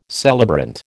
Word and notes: celebrant: American English

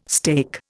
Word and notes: steak: Indian

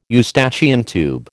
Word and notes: Eustachian tube: American English

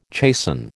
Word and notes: chasten: American English

